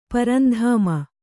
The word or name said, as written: ಪರಂಧಾಮ